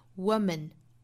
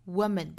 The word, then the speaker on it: woman: 后面的 man 不是 man 的发音了，它是弱读的 man, man, woman, woman 不是 woman 是 woman woman